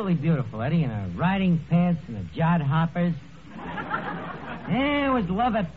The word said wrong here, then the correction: jodhpurs: That word is pronounced jodhpurs.